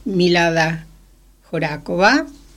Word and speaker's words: Milada Horáková: Tengo aquí el de Milada Horáková, pero... – Qué bueno escucharla bien, bien pronunciada.